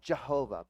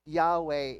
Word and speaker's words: Yahweh: Throughout the scripture, whenever you see an uppercase Lord, L-O-R-D, all in uppercase, what that is the proper name of God called Yahweh. Some people call him Jehovah, but Yahweh is a better pronunciation of that word.